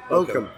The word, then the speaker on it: Bochum: Bayern Munich play Mainz at 6:30, as well as Borussia Dortmund away at Bochum. – Bochum. – I think you call it, pronounce that, yeah.